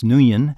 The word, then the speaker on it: Nguyen: The name is Nguyen, which I believe is pronounced Nguyen.